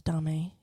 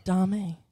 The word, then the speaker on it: Domme: Yes, Domme. Yes. – And, I'm sorry. Am I saying that wrong? – No, it's okay. – No, correct me if I'm wrong. – Domme. – Domme. – It's okay. Dude. Dom. – Dom. – Some people say Dom.